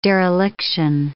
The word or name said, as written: dereliction